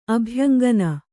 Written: ಅಭ್ಯಂಗನ

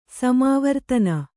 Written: ಸಮಾವರ್ತನ

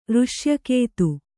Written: ಋಶ್ಯಕೇತು

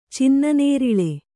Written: ಚಿನ್ನನೇರಿಳೆ

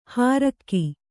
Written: ಹಾರಕು

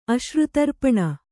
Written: ಅಶ್ರುತರ್ಪಣ

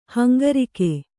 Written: ಹಂಗರಿಕೆ

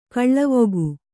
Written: ಗಾಲಿವಕ್ಕಿ

